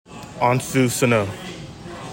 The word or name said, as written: ANSU SANOE